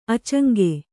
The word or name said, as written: ಆಚಂಗೆ